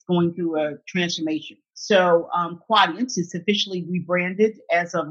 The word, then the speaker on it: Quadient: So you are now with Quadient. Did I pronounce that right? – Yes, Quadient. Yes. – Quadient. And tell me a little bit about the company and how you chose to be there.